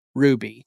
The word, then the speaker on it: RWBY: And their initials create the title, R-W-B-Y, but it's pronounced Ruby.